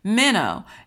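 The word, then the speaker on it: Menno (μένω): Menno.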